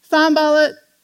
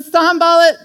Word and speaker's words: Sanballat: Well, let me introduce to you, if I will, Sanballat. Sanballat, and I YouTube the pronunciation of his name. Thank you very much, so you can be confident in that. When Sanballat, the Horonite, Tobiah, the Ammonite official, and Geshem, the Arab, heard about it, they laughed at us, mocking us.